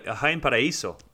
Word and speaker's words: Jaén Paraíso: You say it properly with a semi-Spanish accent. Jaén Paraíso? – It's pretty good. Jaén Paraíso.